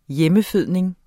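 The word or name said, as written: hjemmefødning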